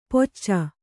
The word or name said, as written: ಪೊಚ್ಚ